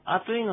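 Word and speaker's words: アトゥイ: で、普通この、あといのトゥーっていうのはですね、とに丸で、をつけて書く、アイヌ語の特殊な発音です。とにちっちゃいうも、うでもいいんですけどね。あとい。あといじゃなく、あといでもないし、あついでもない。あとい。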